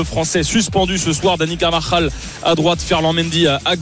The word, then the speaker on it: Carvajal: Carvajal.